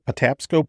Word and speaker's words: Patapsco: Patapsco Park.